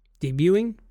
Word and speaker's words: debuting: Debuting? – Debuting. Yeah, I was like, you definitely don't pronounce a T in there. No. I immediately was like, that does not sound right. Debuting our new story trailer and revealing the release date.